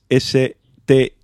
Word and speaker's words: Stine: Este es Stine y el otro es Stein.